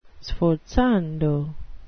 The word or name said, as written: sforzando